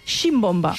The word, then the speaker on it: ximbomba: a les Balears i algunes altres zones no es pronuncia com a simbomba, sinó que en diuen ximbomba. – Ximbomba, amb vics. Amb vics.